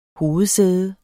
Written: hovedsæde

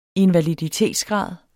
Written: invaliditetsgrad

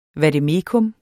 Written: vademecum

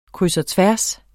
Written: kryds

